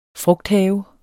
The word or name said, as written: frugthave